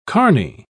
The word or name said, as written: carney